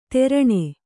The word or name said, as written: ತೆರಣೆ